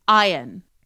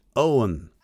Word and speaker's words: Eoin: Okay, next one, E-O-I-N. – Ian. – Ian? – It's pronounced Owen. – Oh. – Owen. – As in the actor, like, Owen Wilson or Clive Owen. Exactly like that.